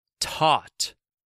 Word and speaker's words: taught: T-A-U-G-H. H T. Silent G H. Once again, taught.